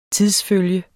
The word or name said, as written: tidsfølge